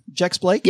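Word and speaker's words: Jex-Blake: I believe that's how you say her name, Jex Blake.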